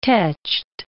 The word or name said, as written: teched